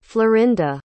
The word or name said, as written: florinda